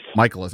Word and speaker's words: Mikolas: All right, so the Nats just signed a new starting pitcher, Miles Mikolas, if I'm saying that right. – Mikolas, okay.